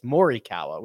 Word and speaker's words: Morikawa: You are now calling him Colin Morikawa, which is the proper way to pronounce that as opposed to Colin Morikawa. But if you hear him say it, it's Morikawa, which is hard, For my brain.